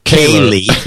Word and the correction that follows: Kohler: pronounced Kaylor. – Kaylor. Corey Kaylor, who got me started on your show.